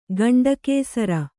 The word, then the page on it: ಗಂಡಕೇಸರ: gaṇḍa kēsara; kannada